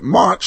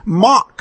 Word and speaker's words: Mach: He pronounced it a soft Mach, a soft, pronounced it Mach, soft CH, and it should be Mach, hard K.